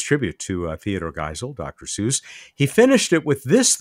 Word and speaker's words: Suess: Edwin King in the UK said that no astronomical feature has yet been named after the physicist Hans Seuss, or Seuss, apparently, but it's spelled S-U-E-S-S, though there are craters on the moon and Mars named after his grandfather, Edward, and an asteroid after his father, Franz Edward.